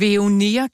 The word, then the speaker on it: Veoneer: Det er ikke nogen hemmelighed, at vi har lidt svært ved at udtale. Det er et selskabsnavn. Veoneer kalder jeg det. Ja, ja. Det er staves V-E-O-N-E-E-R. Altså ja.